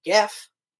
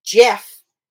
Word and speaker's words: Geoff: But I also followed it up with, you don't call the guy GIF, and it's spelled G-E-O-F-F. Mm-hmm. You call him Jeff, as if it was J-E-F-F, and she told me to shut the fuck up.